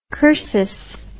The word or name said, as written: cursus